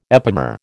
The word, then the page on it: epimer: American English